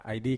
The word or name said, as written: ai di